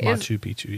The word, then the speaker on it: Machu Picchu: Machu Picchu. Is that how you pronounce that? Okay. – Machu Picchu, yeah.